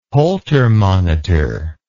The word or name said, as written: Holter monitor